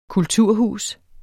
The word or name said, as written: kulturhus